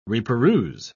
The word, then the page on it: reperuse: British English